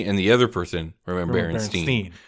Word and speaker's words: Berenstain: I got in a screaming argument with a classmate because I insisted they were mispronouncing Berenstain because he was saying it Berenstein. A and I don't make an E sound, I shouted indignantly.